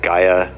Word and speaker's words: Gaia: You can see it's a beautiful hotel, and it is Gaia Napa Valley. I should spell that G-A-I-A, which I guess in Greek stands for Mother Earth.